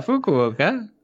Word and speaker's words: Fukuoka: Fukuoka? – Yeah, Fukuoka.